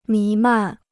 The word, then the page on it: 弥漫: mí màn